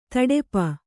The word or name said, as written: ತುಡುಪು